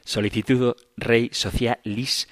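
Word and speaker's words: Sollicitudo Rei Socialis: Dice el Papa Juan Pablo II en Solicitudo Rey Sociales, Socialis, siempre lo digo mal, Solicitudo Rei Socialis,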